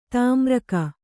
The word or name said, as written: ತಾಮ್ರಕ